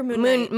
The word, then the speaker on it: Moon Knight: Oh, Moonlight or Moon Knight? – Moon Knight. I said it wrong.